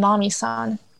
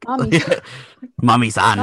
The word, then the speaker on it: Mami-san: What about Still Hungry with Palo Alto and Mami-san? – You say it like san, like Japanese honorific. Mami-san.